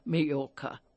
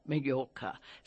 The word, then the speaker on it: Mallorca: That double L, it's spelled M-A-double-L-O-R-C-A, but it's the soft Spanish sound, Mallorca, Mallorca.